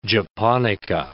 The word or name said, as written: japonica